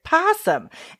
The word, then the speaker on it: PASSM: That's spelled P-A-S-S-M, because in a Chicago accent, you say it possum.